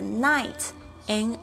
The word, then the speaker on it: night: 那这个 night，N I G H T，注意一下这个 G H 是没有发音的。